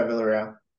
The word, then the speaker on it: Villarreal: Yeah, Villarreal.